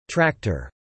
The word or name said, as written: tractor